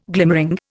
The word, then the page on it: glimmering: Indian